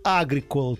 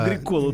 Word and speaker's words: Агрикола: Из Санкт-Петербурга Эйла написала, вы знаете, в финском языке ударение всегда на первый слог. Михаил Агрикола тоже на первый слог. Извините, говорит, слух режет.